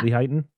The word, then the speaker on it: Lehighton: Lehighton, yeah. – Lehighton, yeah.